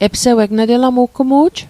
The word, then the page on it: epseweg: Mi'gmaq